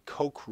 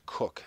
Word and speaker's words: Coke: we've been calling it the Koch rule. It's spelled C-O-K-E, like Coca-Cola, short for Coca-Cola. It's actually pronounced Cook.